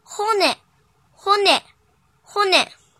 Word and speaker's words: 骨: 训读的时候读作 “hone”，“hone”，“hone”。